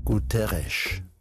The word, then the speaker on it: Guterres: And in Portuguese, an S at the end of a word is pronounced like SH, Guterres.